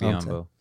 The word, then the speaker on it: Biyombo: I can't really pronounce his homeboy's last name. Adebayo. Biyombo. There you go. – Bismack Biyombo.